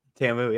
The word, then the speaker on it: TAMU: You have any thoughts on Tamu? Is that how you say it? – Tamu, yeah.